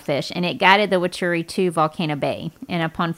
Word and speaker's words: Waturi: And these native islanders were called the Waturi. Did I pronounce that right? – Yes, the Waturi.